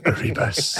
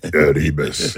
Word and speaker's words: Erebus: You didn't say it right. – Erebus. – Go ahead. – Erebus. – Erebus. Yeah.